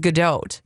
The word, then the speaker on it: Gadot: Oh, Gal Gadot. – Great. You do pronounce the T, but it is Gadot.